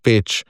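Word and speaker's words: Pécs: Tako bo recimo peč na Madžarskem zapisan z peč cs, ne, torej cs bo za č.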